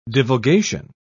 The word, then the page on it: divulgation: British English